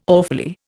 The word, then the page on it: awfully: Indian